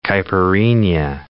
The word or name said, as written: caipirinha